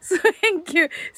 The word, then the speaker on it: thank you: スウェンキュー、スウェンキューって言いますけれどもね。そうそう、ね、実際はね、 th の発音ですのでね、舌先をね、軽く噛んで、息をね、たくさん吐く感じでね、発音するので、ね、いろんなね、こう、表現になりますよね、日本語にね。